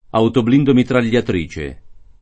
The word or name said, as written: autoblindo